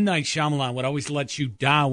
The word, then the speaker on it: Shyamalan: Night Shyamalan? – Shyamalan. You said it right the first time.